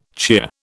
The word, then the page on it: cheer: British English